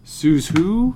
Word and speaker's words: Suzhou: Suzhou.